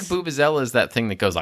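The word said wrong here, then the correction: vuvuzela: It's not Boobazella, like Boo Boo the Bear. It's Vuvuzella. – Oh. – Like, I've been saying it wrong my whole life. – Your whole life. You were today years yeah. old when you realized it was a V, not a B.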